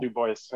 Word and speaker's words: DuBois: But yeah, I go with the US DuBois and the hard G Gilliard. – That's the Idaho right. DuBois. So yeah. There's a town in Idaho called DuBois and it was very close to where I grew up.